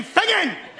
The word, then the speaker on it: Finzean: G-A-R-I-O-C-H. – And the town? – Oh, Lord, give me strength. It's Fingen. F-I-N-Z-E-A-N. – Could you spell it properly, please? – I did. F-I-N-Z-E-A-N. Fingen!